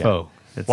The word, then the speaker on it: Faux: Why is it FOE? – It's not. – It's French. – AUX. Are you seeing FOE here? – No, I meant that. Well, that's how you say it. – Yes. – Yes. Faux is French for false.